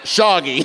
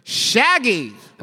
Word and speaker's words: Shaggy: Long A, long Y. Shaggy. – Oh, I thought you meant Shoggy. – Shoggy. – Ladies and gentlemen, Shoggy. – Shoggy. It was Shaggy.